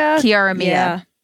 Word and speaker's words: Kiara Mia: which is called Keeping Up with Kiara Marie. Wait, am I butchering the name? – Kiara Mia.